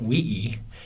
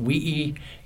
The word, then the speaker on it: uii: So my favorite word actually is a Samoan word, and it is spelled U-I-I. It's pronounced wii, and it means the youngest in the family.